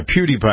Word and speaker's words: PewDiePie: PewDiePie. – Is that how you pronounce it? – Yeah. – PewDiePie. – Like PewDiePie, but PewDiePie.